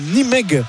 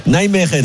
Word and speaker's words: Nijmegen: J'ai pas d'anecdote sur Onal, si ce n'est qu'il arrive donc de Nimeg.